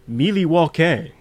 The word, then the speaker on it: Milwaukee: "Yes, and actually it's pronounced Milwaukee."